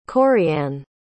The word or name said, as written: Coriann